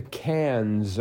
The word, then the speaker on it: Cannes: And then finally, 28 across, first showing at a film festival in France, the Cannes opener. – Mm-hmm. – I don't know if you should really pronounce the S or not, but no.